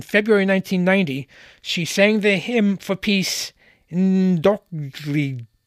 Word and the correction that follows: N'Dokolidji: It's N'Dokolidji. You pronounce it.